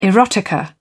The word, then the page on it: erotica: British English